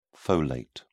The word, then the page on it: folate: British English